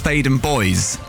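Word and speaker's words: Theydon Bois: There's a place in Essex that I grew up near, which is pronounced Thaden Boys, okay? Thaden Boys, stupid name anyway. But the boys bit of it is spelt B-O-I-S.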